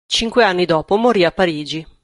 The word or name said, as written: anni